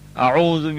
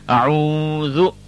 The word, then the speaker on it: A'udhu: Now, A'udhu, the first is an alif and the second is a ayn. The pronunciation of the alif is a, but the ayn is a letter that has to come from the throat. It has a guttural sound. So we have to say ah, ah, a'u, a'udhu.